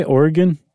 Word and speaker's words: Oregon: Isn't that how they say it? Oregon? – Oregon.